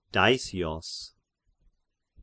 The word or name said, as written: Daisios